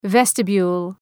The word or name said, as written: vestibule